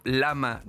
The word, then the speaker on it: Llama: es el modelo Lama 2, se escribe Llama, l A M A, es Ay, ¿cómo se dice la L? – Lima.